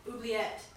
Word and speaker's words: Oubliette: So the dungeon was actually known as the, I don't know how to say this, Oubliette? – Oubliette.